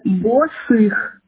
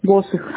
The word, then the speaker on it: Босых: Босых Ксения Степановна. – Босых, правильно? – Нет, Босых, первое Б. – Босых, ударение, правильно? – О, Босых.